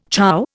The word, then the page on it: chow: Indian